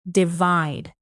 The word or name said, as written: divide